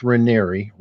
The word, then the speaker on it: Raniere: Now, Nexium is the cult that was led by Keith Raniere, rhymes with canary, or said like canaria, and he just got put away for 120 years.